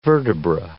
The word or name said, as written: vertebra